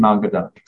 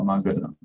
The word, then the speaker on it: Magadha: So 大军, 小军是住在社卫国, 跑到摩, 摩嘎达, 摩嘎陀, 哦, 摩切陀, you can pronounce as 摩切陀 or 摩嘎陀, it's basically Magadha, or Magadha.